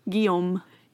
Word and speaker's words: Guillaume: It's like the French trying to pronounce that I-A-M. – Yeah. Guillaume.